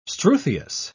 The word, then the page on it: struthious: British English